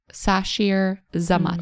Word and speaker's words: Sasheer Zamata: Her name is Zashir Zamata, I think is how you say it. Sashir Zamata. – Mm-hmm. Right. So it's S- Shout out. – S-A-S-H-E-E-R. Z-A-M-A-T-A.